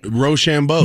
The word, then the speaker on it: Rochambeau: And they looked at me, and they said, okay, do you want to lead Rochambeau? And I go, what is that? – What do you mean? – Did I even say it right? – Rochambeau. – Rochambeau. Thank you.